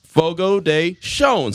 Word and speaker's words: Fogo de Chão: pronounced Fogo de Chão, spelled S-H-O-W-N.